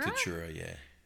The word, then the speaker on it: Tatura: And he went and filmed your mum and your auntie Pat in a town called, now, am I going to say it right? Tatura? – Tatura, yeah.